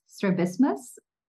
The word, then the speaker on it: strabismus: and correct me if I'm not pronouncing this correctly, is it strabismus? – Yeah, correct. – Strabismus, okay, got it right.